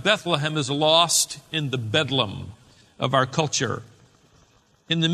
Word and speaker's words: Bedlam: St. Mary's of Bethlehem was shortened over time and simply pronounced Bedlam.